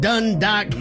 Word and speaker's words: Dundalk: We got Jamie on the line representing out of Dundalk, Maryland this morning. – I don't know why I laugh every time you say the city. – Am I saying it properly? – You are saying it exactly right.